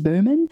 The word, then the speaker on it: Boehman: And her last name is spelled B-O-E-H-M-A-N, Stacey Berman.